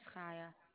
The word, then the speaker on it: Chaya: My name is Chaya. – The name again, can you spell it for me, please? – C-H-A-Y-A. – C-H-A-Y-A.